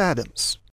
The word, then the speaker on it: Adams: I'm Peter Adams. – Spell it, please. – A-D-A-M-S.